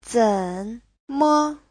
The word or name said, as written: zen3me5